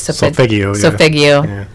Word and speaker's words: solfeggio: Am I saying that right? – Solfeggio. – Yeah.